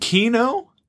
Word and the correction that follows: Kimo: Is that how you pronounce it? – Yeah, Kimo.